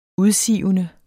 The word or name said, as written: udsivende